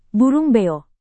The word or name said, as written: burung beo